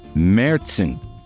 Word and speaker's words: Märzen: Now featuring two locally made German-style Märzen beers. I said it right. They have the umlaut over the A like Motley Crue.